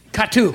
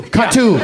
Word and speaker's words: KATU: Katu. – Katu. Yeah. – It's an old Korean station. Katu. K-A-T-U is how we spell it.